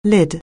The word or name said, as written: lid